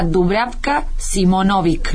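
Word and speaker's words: Dubravka Šimonović: que se llama Dubravka Simonovic. ¿Lo dije bien? – Sí. – Muy bien.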